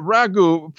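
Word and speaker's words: ragout: But ragu for people not uh, familiar with this, this isn't like some you know sp- you know spaghetti sauce in a can.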